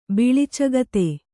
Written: ಬಿಳಿಚಗತೆ

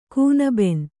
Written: ಕೂನಬೆನ್

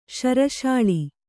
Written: ಶರಶಾಳಿ

